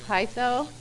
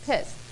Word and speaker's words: peitho: And this word persuaded, and it actually comes from a Greek word, pitho, P-E-I-T-H-O, but in Aramaic it's pronounced pis, P-I-S.